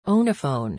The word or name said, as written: own a phone